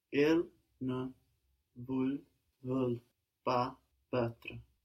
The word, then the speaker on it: perdre: Il ne veut rien perdre. – Very good. If you put more accent on the bit at the beginning, perdre, and let the rest kind of fall out, I think you will find the pronunciation easier.